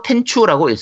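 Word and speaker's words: 天誅: 일본어로 텐추라고 읽습니다.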